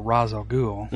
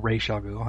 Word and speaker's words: Ra's al Ghul: or Ra's al Ghul, however you want to pronounce it. – It's Ra's, for us people. I know it's... Us old people that didn't have TV and movies. – I've always said Ra's, but...